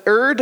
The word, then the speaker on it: erred: Well, the answer can be found also in the confession on page 5, that we all have erred, and that's actually the proper pronunciation, erred, I was informed by a bishop the other day, erred and strayed from thy ways.